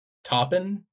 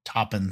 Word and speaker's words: Taupin: Taupin. Taupin.